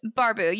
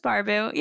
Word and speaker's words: Barbu: It's Barbu. You said it right. Yes, okay, sweet. You said it totally fine. So many people are like, is it Barbu? Barbu? I'm like, just Barbu. You're good.